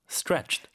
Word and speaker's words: stretched: 이거를 쭉 이어 발음하니까 stretched. – stretch. – stretch. – 굉장히 쉬워 보이잖아요. 근데 연습을 우리가 많이 해야겠더라고요. stretch, stretch, stretched across 이렇게 yeah. 되네요. – 스트레라기보다는 네. 약간 뜨 발음이죠.